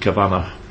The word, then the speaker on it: Cavanagh: Cavanagh, his name's pronounced Cavanagh rather than Cavanagh.